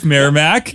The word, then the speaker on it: Merrimack: Merrimack.